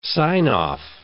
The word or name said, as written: sign-off